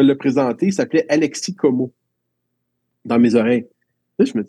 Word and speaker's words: Komu: A-L-E-K-S-I. Puis Como, ça se prononce, ça s'écrit K-O-M-U. Mais le O, eux autres, il est comme fermé un peu.